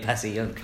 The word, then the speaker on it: Passyunk: I say it Passyunk.